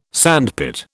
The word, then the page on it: sandpit: British English